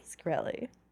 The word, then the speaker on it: Shkreli: Say it correctly, Shkreli. Shkreli? – Shkreli.